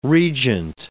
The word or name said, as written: regent